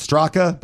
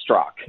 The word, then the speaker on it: Straka: It's actually pronounced Strock. – There you go, Brendan. I wanted you to correct that. – Does it say, how's it spelled? It says Strock, yes, but it's Strock. The okay. A is silent.